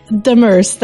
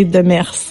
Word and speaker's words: Demers: Demers. That's Demers. How I see it. But in French, we say Demers.